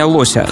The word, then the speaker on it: лося: И да, для всех блюстителей русского языка, я знаю, что правильное ударение лося, но на бирже так не говорят.